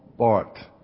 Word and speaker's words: Barth: The name is Karl Barth, B-A-R-T-H. You pronounce it with a hard T in German.